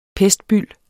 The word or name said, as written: pestbyld